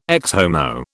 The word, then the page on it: Ecce Homo: British English